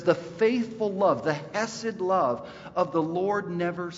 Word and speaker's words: hesed: hesed. Everyone say hesed. Ready? Hesed. All right, did you spit on the person in front of you? If you said it right.